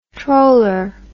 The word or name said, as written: troller